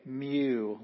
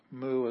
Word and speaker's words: mu: Some say mew, like a kitty cat or something, but mu is how I learned it. And most of the people I know of say mu.